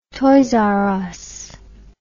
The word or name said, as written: toysrus